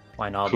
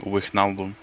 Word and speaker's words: Wijnaldum: Sorry, how do you guys pronounce his name? – Wijnaldum. – Who? Gini? – Yeah. – Gini Wijnaldum or Jorginho Wijnaldum. – Okay. Yeah, I don't know how to say his name properly. – I've heard people call him Wijnaldum, which makes absolutely no fucking sense.